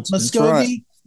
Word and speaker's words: Muscogee: Robbie is an evangelist of the Cherokee, and let me say this right, is it Muscogee? – Yes. – Muscogee Creek Native American Heritage.